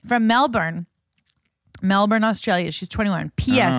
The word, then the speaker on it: Melbourne: Melbourne. Erin, she spells it E-R-I-N-N-A.